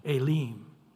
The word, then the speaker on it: Elim: So the E has an A sound and the I has an E sound, Elim. And then if you say, how do you pronounce S-I-N, it's actually seen is the way they pronounce it.